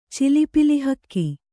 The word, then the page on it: ಚಿಲಿಪಿಲಿಹಕ್ಕಿ: cili pili hakki